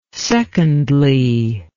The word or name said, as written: secondly